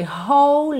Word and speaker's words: whole: Atenção à pronúncia dessa palavra, tá? Porque é bem diferente do que aparenta quando a gente lê. Então, ó, W-H-O-L-E, whole, whole. Whole life. Eu junto tudo aqui porque é como se eu tivesse dois L's ligados, já que o E do whole não é pronunciado, tá?